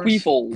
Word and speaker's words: Queefles: The Magic School Bus going up against the Philadelphia Queefers. Do I pronounce that right? Queefers? – Queefles. – Queefles. – Queefles. – Queefles. Okay. – Like Nick Foles.